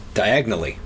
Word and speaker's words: Diagon Alley: that you could also pronounce it diagonally.